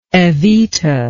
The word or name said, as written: evita